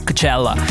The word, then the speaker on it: Coachella: Anzi, bisogna anche qua pronunciarlo come lo pronuncerebbero loro, cioè il Coachella.